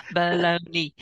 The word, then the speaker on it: baloney: It's just baloney. Just three syllables.